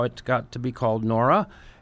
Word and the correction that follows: Nora: And they corrected me that I wasn't pronouncing it right. It's Nura. So you got to roll your R's.